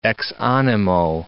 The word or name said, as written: ex animo